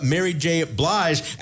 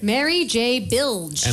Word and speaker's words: Blige: Next, we wanted to know how Valerie mispronounced the name of Mary J. Blige. What did Lilan say? – Mary J. Bilge.